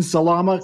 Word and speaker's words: Salama: That's Martin Salama, kind of like salami with an A.